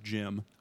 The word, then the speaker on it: Djinn: It's actually pronounced Jim. – Ah, Jim Uthul. – Yeah, no, yeah. – Yeah. – The Jim. – Yeah, the Jim Uthul. – Yeah, it's D-J-I-M. D's Jim.